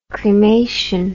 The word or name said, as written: cremation